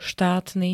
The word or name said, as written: štátny